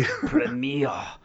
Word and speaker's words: Premiere: That's because it's French, you can say it right. – Premiere.